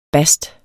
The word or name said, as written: bast